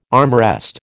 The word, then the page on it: armrest: American English